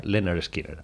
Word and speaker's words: Lynyrd Skynyrd: Pues se pronuncia Lynyrd Skynyrd, así se llama.